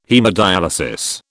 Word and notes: haemodyalisis: British English

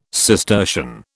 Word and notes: Cistercian: British English

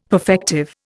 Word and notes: perfective: Indian

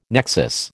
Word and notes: nexus: American English

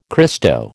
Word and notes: Christo: American English

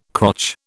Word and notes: crotch: British English

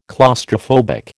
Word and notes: claustrophobic: American English

